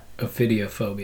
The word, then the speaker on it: ophidiophobia: Does anybody know how to pronounce that? – Ophidiophobia.